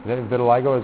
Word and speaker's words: vitiligo: Have you ever heard of vitiligo? Vitiligo, is that, am I saying that right? I believe so.